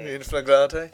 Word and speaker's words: in flagrante: In flagrante. Is that the correct pronunciation of that? – Right, yes.